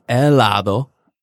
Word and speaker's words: helado: It's helado or is it helado? – It's helado. – Helado.